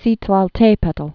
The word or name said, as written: Citlaltepetl